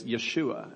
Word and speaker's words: Yeshua: In fact, Jesus's name in the New Testament, if you pronounce the Hebrew way, is Yeshua, and it's very similar to Hosea, really the same Hebrew root, this idea of salvation and saving.